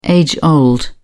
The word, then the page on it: age old: British English